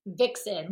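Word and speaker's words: Vicsen: Yeah, no, it's Vixen, like how we've always heard like what a Vixen is. So it's that, but it's spelled Vic like Victoria.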